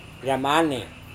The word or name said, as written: ramane